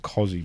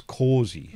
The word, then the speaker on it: Kosi: I've been saying Cosi, but because it's spelled K-O-S-I, but apparently... – K-O-S-I. – Cosi. Yeah, but apparently it's Corsi.